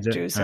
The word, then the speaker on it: drusen: And it's drusen, right? With a Z sound? – That's right, drusen.